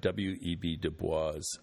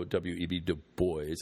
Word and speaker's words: Du Bois: And for those of you who don't know, W.E.B. Du Bois, I think is the proper way to say it, W.E.B.